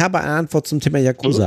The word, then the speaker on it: Yakuza: Also, ta ta tatsächlich, ähm, also Yakuza wird im Japanischen tatsächlich Yakuza ausgesprochen. Ah, und ah. und ähm, tatsächlich ist die Betonung neutral. Also hier heißt es wörtlich: No stress on any part in particular. A tiny bit more on the ya, if you will, but in general flat.